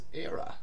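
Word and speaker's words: Éire: And that word is era, rather than air, because that's how you pronounce era in Irish, Ireland in Irish.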